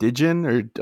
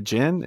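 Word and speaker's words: Djinn: I don't know how to, is it Dijin or Jin? It's a DJ. A Jin? Is it that? – Yeah, the D is silent.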